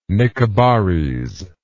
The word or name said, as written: Nicobarese